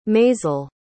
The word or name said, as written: maysel